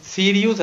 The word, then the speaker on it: Sirius: No, pero Sirius es como si se escribe como lo dices tú, Faust, pero es Sirius así como se escucha, y acá le ponen Cyrus.